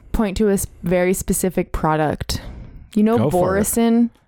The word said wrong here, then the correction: Boisson: How do you pronounce it? – Boisson. – Oh, boisson.